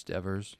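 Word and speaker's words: Deavor: Yeah, I mean, it should be pronounced Devers, by the way they spell it. But yeah, it's Devers.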